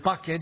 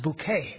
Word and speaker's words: Bucket: Of course, she doesn't like the word bucket. She insists that her married name is pronounced bouquet. Even though her husband Richard always says, it was always bucket until I met you.